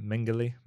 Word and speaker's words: Mengele: Mengele.